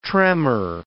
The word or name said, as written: tremor